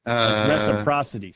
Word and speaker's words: reciprocity: Reciprocity. Sorry. – Reciprocity. – I was adding an extra syllable there.